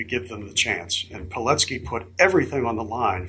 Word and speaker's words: Pilecki: Pilecki.